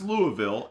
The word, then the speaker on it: Louisville: And it's pronounced Louisville, as I have been told. – I'm sure it's Louisville, but go ahead. – No, it's Louisville, as people from there have told me, and they gave me some shit about it.